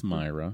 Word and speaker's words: Mira: It's pronounced Myra. – Myra. – Looks like Mira.